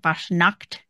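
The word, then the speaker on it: Fasnacht: Fashna is the event, or Fashnacht, I guess is how you say it. Fashnacht. That's how you say it. I learned that by watching Chip's video. Well, Brian's video for Chipmunk Travel Series, whatever. He released the Fashnacht video last week, I think. And talked to the organizer of the event, and she said it. I was like, "Oh, we've all been saying it wrong."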